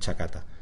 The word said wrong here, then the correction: chataca: Chataca. – Chataca, ¿ves? Siempre lo digo al revés.